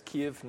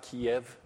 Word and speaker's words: Kyiv: That is Kyiv, not Kiev. Kiev is the Russian pronunciation.